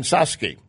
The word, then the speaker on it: Psaki: She did leave CNN, become a senior advisor at the Biden-Harris transition team, and now press secretary Jen Psaki, P-S-A-K-I. I'm told the P is silent.